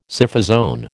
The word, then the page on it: Scyphozoan: American English